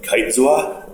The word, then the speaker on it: Kitezoa: And even Kitezoa, like if you pronounce it in Creole, it sounds cool. But a lot of people are like, Kitezoa? I'm like, no, it's not Kitezoa.